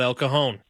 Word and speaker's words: El Cajon: Take a listen as Jimmy Johnson, El Cajon's own, joined us this morning, and he starts off talking about El Cajon. – Thank you. I'm so happy to hear El Cajon pronounced correctly.